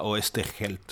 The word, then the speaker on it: Oesterheld: Alors, les Argentins, ils prononcent ça Oosterheld. Voilà. Mais il est d'origine allemande, donc ses aïeuls devaient s'appeler Oosterheld.